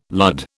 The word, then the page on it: lud: British English